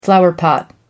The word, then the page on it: flowerpot: American English